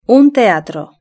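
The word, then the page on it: un teatro: Spanish (Spain)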